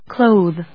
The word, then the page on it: clothe: klóʊð ｜ klˈəʊð